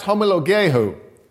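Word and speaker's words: homologeo (ὁμολογέω): Now, the word that is translate declare or confess in our case is the word, the Greek word, and it's pronounced homologeo. Homologeo. And if you break that word down, it's homo, which means same, and logeo, which means to speak.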